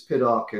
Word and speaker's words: Piddock: Yeah, people say, how do you pronounce it in America? I go, it's Piddock in English, it's Piddock in America, but most people just call me shithead, you know, so however you want to pronounce it.